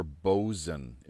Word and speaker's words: boatswain: Boatswain is something you'll commonly hear, but it's because of the pronunciation of the American, but English people will say not boatswain, boatswain. – Yeah, here it says it's boatswain, as you say, or bosun is what it's telling us here.